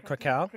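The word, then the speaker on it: Kraków: Oh, yeah, Krakow.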